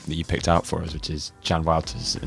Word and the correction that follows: Juan Wouters: and Millionaire. – Juan Wouters. – Juan Wouters, thank you. – Yeah. – Saving me with the pronunciation.